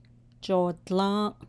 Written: joodlą́ą́’

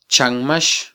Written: chanmax

